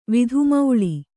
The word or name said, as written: ವಿಧುಮೌಳಿ